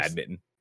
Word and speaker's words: badminton: And first off, I appreciate the excellent pronunciation from One Blessing, because I just call it badminton. You know what I mean? I know there's a T in there, but I just don't care.